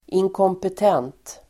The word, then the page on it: inkompetent: [inkåmpet'en:t]